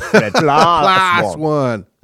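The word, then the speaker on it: PLOS ONE: And so what's interesting about this one, and it was reported in the January 25th PLOS-1, and they talk, so I think you're supposed to say that with a Harvard accent. – PLOS-1. – Is, I believe, how it's pronounced.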